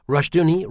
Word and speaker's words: Rushdoony: Rushdoony, rhymes with Rush Looney, pioneered the Christian homeschooling movement and came up with the revisionist history that paints the U.S.